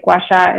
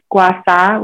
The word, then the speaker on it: gua sha: And if you were to translate gua sha, it means scraping sand. And you might also hear it pronounced gua sa, which is the Cantonese pronunciation of it.